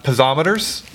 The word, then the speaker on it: piezometers: piezometers.